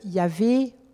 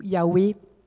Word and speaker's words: Yahweh: Mais il semblerait que la prononciation correcte de ce, de ce mot mystérieux euh, se rapprocherait davantage de Yahvé ou Yahweh.